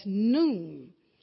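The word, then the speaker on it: nun: And that Hebrew alphabet, even though it may appear to look like the word none, but it's pronounced the word as noon.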